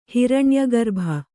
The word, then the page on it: ಹಿರಣ್ಯಗರ್ಭ: hiraṇya garbha